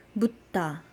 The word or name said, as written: mudda